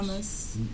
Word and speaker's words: Yamas: We're in the studio of MC Lamas. Is it, do you pronounce it? – Yeah, Yamas. – Yamas. – Yamas, yeah. Yamas. It's a Spanish thing.